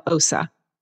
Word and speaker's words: Ossa: And it was when we came to America that my parents changed my name from Ossa to what it is now, Ossa.